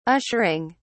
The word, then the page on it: ushering: British English